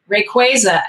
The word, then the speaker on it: Rayquaza: Is it Rayquaza? – Rayquaza.